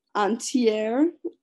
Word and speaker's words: antier: But the word is entier, entier. That's a French pronunciation. – Antier.